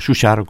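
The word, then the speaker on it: Schuchardt: o Schuchar, como se pronuncia en alemán,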